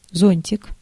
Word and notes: зонтик: /ˈzonʲtʲɪk/

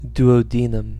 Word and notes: duodenum: /djuːə(ʊ)ˈdiːnəm/

